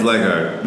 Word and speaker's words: Leckart: It's Leckart.